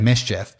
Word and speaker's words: MSCHF: MSCHF, but it said Mischief.